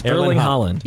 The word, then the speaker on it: Erling Haaland: Okay, Tom Holland DM'd Erling Haaland. – Yes. – Erling Haaland. – No, you're making it harder than it is. Erling Haaland.